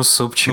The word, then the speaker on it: SP4K: Почему Супчик? – Ну, потому что его ник читается как Супчик.